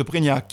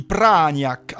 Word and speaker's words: Prégnac: c'est la famille d'Alot de Prégnac qui me l'a dit. On ne dit pas Prégnac, on dit Pragnac, avec un accent tonique sur Pra.